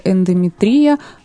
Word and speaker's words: эндометрия: По гинекологии беспокоит дисплазия шейки матки, две фолликулярных кисты на левом яичнике, гиперплазия эндометрия. Правильное ударение? – Эндометрия.